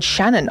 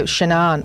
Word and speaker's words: Shanann: So there's that, and she also wants us to know about the Chris and you say Shanann Watts. I've always said Shannon. I didn't know Shanann. I didn't know how to pronounce her name, but of course, this is the famous murder, and the documentary is the Netflix documentary American Murder, The Family Next Door.